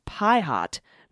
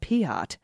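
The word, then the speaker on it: Piatt: Just a slight correction, General Piat's name is actually pronounced Pie Hot, not Piat.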